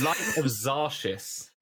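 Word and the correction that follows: Xaryxis: Lights of Zarixis, that is what it's called, really.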